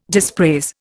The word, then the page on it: dispraise: Indian